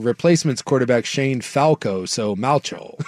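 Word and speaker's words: Malchow: It's pronounced like the know. Replacement's quarterback, Shane Falco, so Malcho. I guess I can't get over the way it looks. – Malcho. – Because it's M-A-L-C-H-O-W.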